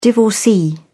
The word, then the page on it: divorcee: British English